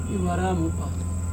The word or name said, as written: ivaramupa